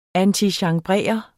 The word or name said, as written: antichambrere